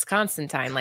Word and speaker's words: Constantine: It's Constantine, like Clementine. Which bothered me because Keanu Reeves actually did an interview and he was saying, "Oh, I would love to, reprise my role as Constantine."